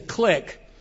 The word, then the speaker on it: clique: Oftentimes, I used to say clique, and so just so you know what I mean, a clique are the same thing. I think the proper pronunciation is clique. It's C-L-I-Q-U-E.